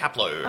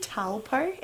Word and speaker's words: Taplow: I can't say his name. – Taplow. – I keep on saying Talpo. Anyway, Taplow.